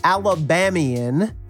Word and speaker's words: Alabamian: Welcome, everybody, to another of our Friday episodes, and I am joined today by our favorite Alabamian. Am I saying that right? Kyra Perkins. – That is correct.